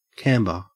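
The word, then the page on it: camber: au Australian English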